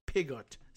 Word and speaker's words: Pigott: the State Department spokesman Tommy Pigott said. Rhymes with bigot, but it's spelled with a P and two T's.